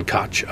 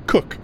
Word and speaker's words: Koch: Captain Koch, I assume, I said. – It's spelled Koch, but it's pronounced Cook.